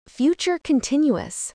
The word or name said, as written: future continuous